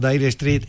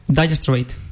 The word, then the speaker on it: Dire Straits: Dire Straits o Dire Street, ¿cómo se dice? ¿Cómo es en inglés? – Dire Straits.